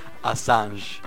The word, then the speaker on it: Assange: De Julián, y quiero que pronuncie el apellido porque lo hace muy bien. – Assange. – Le mete una nasal parisina interesantísima.